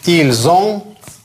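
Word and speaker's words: ils ont: То есть эти буквы «С» начинают читаться, потому что следующая буква – гласная. – Как «С» читается? «З», как «З». «Ильзон», «эльзон». Еще раз.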